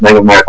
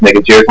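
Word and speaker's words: Megaguirus: I always thought it was Mega Mare. I don't know why. That's kind of how I always pronounce it. And then when I heard it's Mega Jiris, I'm like, oh, that makes better sense.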